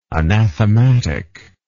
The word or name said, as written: anathematically